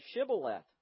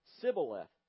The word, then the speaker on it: Shibboleth: And if he said no, then they would say to him, "Say Shibboleth." But he said Sibboleth, for he could not pronounce it correctly.